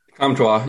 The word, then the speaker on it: Comtois: How do you say his last name? Do you know? – Comtois. You said it right the first time. – Comtois? Is that it? – Comtois.